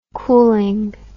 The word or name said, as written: cooling